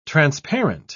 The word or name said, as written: transparent